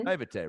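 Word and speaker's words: naïveté: How do you say that word? – Mm-hmm. – Naivete, right? Yeah. Of just what you're supposed to be doing.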